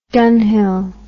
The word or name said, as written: dunhill